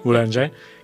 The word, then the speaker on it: Boulanger: Boulanger. – Que es Lili y Nadia. Y bueno, voy a leer un poquito de ese proyecto, cómo va, acompañando de su música, ¿no? Dice: Lili y Nadia Boulanger,